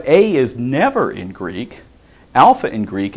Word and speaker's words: alpha: So we start out with alpha, and the A sound is in father.